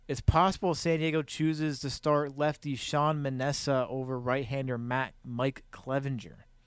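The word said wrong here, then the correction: Manaea: Sean Manea, I think. – Yeah, sorry. I mispronounced that.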